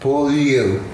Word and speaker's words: Beaulieu: It's pronounced Beaulieu. – What? – It's a French meaning, beautiful place.